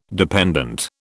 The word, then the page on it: dependant: British English